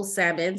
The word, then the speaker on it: Salmon: So if you get the name right, Nicole O, don't forget the O, Nicole O Salmon. Salmon is spelled just like the fish.